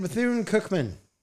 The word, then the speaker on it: Bethune-Cookman: Southern at Bethune-Cookman.